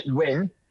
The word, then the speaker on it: Nguyen: It's a bit like Win. You stress that Nguyen, Perfect.